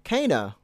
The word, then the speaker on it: Kena: for some reason, it's spelled Kena, but when you hear it in the game, it's like, Kena.